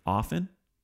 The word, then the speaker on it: often: Often, the T is silent. It's a silent. It actually is a silent T. Don't say often.